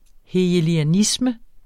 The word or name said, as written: HEGELIANISME